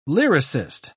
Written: lyricist

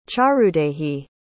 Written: charudehi

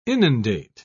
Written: inundate

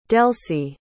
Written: delsie

